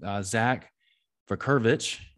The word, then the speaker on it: Vucurevich: I've got a very cool guest lined up on the line today, Zack Vucurevich.